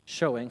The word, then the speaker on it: shewing: In a work titled A Summary of Church Discipline, shewing, yes, that's the word and the pronunciation, showing for us, the qualifications and duties of the officers and members of a gospel church.